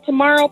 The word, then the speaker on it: Tomorrow: I'm there under Tomorrow Peterson.